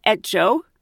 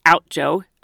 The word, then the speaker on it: Outjo: You just drive back an hour to Etjo. I pronounced it Outjo, O-U-T-J-O. But she's calling it Etjo and she's from there.